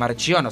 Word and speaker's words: Marchiono: Marchiono, porque en realidad no es Marchiono. Si es italiano es Marchiono con CH.